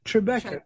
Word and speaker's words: Tribeca: Tribeca.